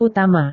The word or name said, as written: utama